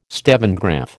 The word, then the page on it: stevengraph: American English